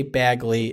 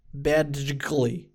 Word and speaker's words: Badgley: It is an interesting combination of consonants. D-G-L all in a row. Badgley.